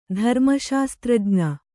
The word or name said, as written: ಧರ್ಮಶಾಸ್ತ್ರಜ್ಞ